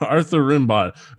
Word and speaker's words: Rimbaud: As soon as he said it, I'm like, wait, did he pronounce it Arthur Rimbaud? – Arthur Rimbaud. Yeah, they pulled that poem out of obscurity, huh? – One of the most famous French poets.